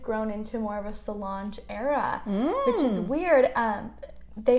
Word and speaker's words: Solange: Is it Solange? – Yeah.